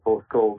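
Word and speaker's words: Porthcawl: I am laughing as you are raving about Royal Portcawl.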